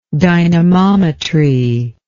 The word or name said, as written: dynamometrical